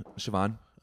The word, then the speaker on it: Siobhan: Siobhan. – Oh. – But it's Irish, so it's spelled Sayo Bahan.